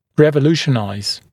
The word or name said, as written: revolutionize